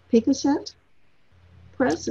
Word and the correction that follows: Pyquasset: Is that how you pronounce it? Pyquasset Press.